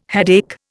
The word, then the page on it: headache: Indian